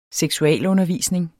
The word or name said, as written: seksualundervisning